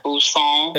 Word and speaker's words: Busan: Busan.